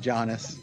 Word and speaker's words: Giannis: Mr. Antetokounmpo himself, Braun Strowman's favorite member of the Milwaukee Bucks is Giannis. – Yes, and thank you very much for pronouncing it properly, or at least as properly as we know.